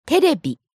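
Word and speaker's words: terebi (テレビ): Ah, terebi.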